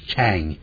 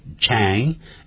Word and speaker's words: Chang: My family name is Chang. 我呢姓张。如果是老中文，我可以念成张。可是老外呢，Chang，他念 Chang 啊，他这个他没有办法像我们发音讲中文那么准的。所以刚开始的时候，好，我们这句话就这么说：My family name is Chang.